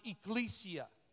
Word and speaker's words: ecclesia: Now the church in the Greek word, the Greek word for church is this word called, it's pronounced ecclesia. Ecclesia.